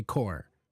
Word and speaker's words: corps: It's funny because the last one is C-O-R-P-S, and we don't pronounce the P or the S. We say core, like the Marine Corps.